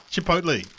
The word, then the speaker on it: chipotle: Well, you can say it properly. What is it? Chipotle.